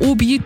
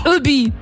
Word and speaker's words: OBI: Und das ist keine Abkürzung, aber Obi, das ist einfach nur Hobby auf Französisch ausgesprochen. Obi.